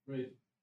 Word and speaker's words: Wraith: Am I saying that right? Rolls Royce Wrath? – Wraith. – Rolls Royce Wraith.